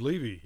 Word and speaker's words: Levy: Marv Levy, yes. – Levy, is it? – Yeah. – Okay. – Former NFL coach. – Not a person I recall, so I mispronounced it.